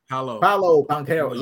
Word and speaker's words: Paolo Banchero: Paolo Banchero.